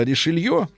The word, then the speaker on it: Ришелье: День одураченных э, — это 1630 год, э, когда влиятельные враги, враги кардинала Ришелье, надо правильно фамилию с Ё говорить,